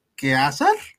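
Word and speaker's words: Caesar: Aquí nos comentan, la correcta pronunciación de César es que es que azar. No sé si lo estoy diciendo bien. – Eh, bueno, el latín tiene diferentes pronunciaciones hoy en día para la pronunciación clásica, eh, digamos de las más estudiadas, la C se pronuncia como K, efectivamente.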